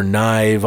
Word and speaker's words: Gnyve: Look for Knive on all the shit. The G is silent, G-N-Y-V-E.